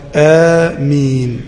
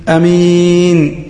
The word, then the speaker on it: آمين: القسمان الذين ينطقونها خطأ: القسم الأول يقطعها قطعا فيقول الإمام غير المغضوب عليهم ولا الضالين، مصلين، فيقول أمين أمين، يقطعها أمين. هذا اسم رجل اسمه أمين، عمك الحج أمين، هذا خطأ. وآخر يزيد في مدها على حرف المد فيقول غير المغضوب عليهم ولا الضالين، فيقول آمين.